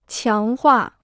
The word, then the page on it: 强化: qiáng huà